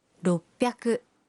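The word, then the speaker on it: roppyaku: 600 se convierte en ropyaku.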